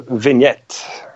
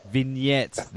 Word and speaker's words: vignette: Well, dude, yeah, your band, of course, Letters from the Colony, your new album comes out tomorrow, which is Vignette, is that how you pronounce that? – Yeah, it's actually a bit surprised how many, you know, native English speakers can't pronounce that, because I think it's an English word. It's a vignette. – Vignette. There we go. – Vignette. – Okay. – Jeez, silent. Maybe it's an Aussie thing.